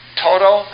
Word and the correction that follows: Tito: Am I saying it right? – Tito.